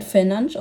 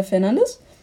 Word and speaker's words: Fernandes: Also er wird also er sagt, er wird Fernandes ausgesprochen, aber wir Deutschen sagen äh, Fernandes.